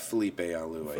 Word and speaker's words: Felipe Alou: Is it Philippe Alou or was it Felipe or Philippe? How did they pronounce that? Felipe Alou. F- Felipe Alou.